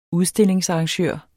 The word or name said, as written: udstillingsarrangør